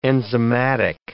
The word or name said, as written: enzymatic